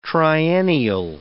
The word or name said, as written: triennial